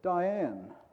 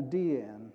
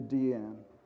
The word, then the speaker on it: Diane: The other thing that I learned in the video, for 50 years I've been calling Arbus Diane, but In the video, her daughter, who should know how her name's pronounced, called her Deanne. So we'll try to stick to Deanne.